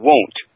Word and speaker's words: Wundt: There was a gentleman in history by the name of Wilhelm Wundt. Wundt, and that's spelled W-U-N-D-T.